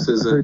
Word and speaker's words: SZA: SZA, yes.